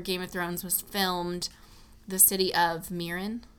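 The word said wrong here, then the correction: Meereen: Marine. – Mm-hmm. I always mispronounce that. Marine. Anyway, you feel like Khaleesi when you walk around the Klis Fortress, for sure.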